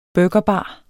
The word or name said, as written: burgerbar